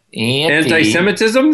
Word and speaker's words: anti-Semitism: When you say it like that, Dane, it sounds like anti-Semiticism. – Anti-Semiticism? – Yeah. – Anti-Semiticism? Is that what you... – Yeah. – I thought there was a new person in town. Anti-Semiticism. – Anti-Semitism? Semitism, yes.